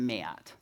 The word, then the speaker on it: Matt: Enough that she pronounces my name with two syllables. My name is not Matt, it's Matt. Oh, hi, Matt, like that.